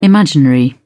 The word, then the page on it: imaginary: British English